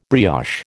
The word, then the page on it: brioche: American English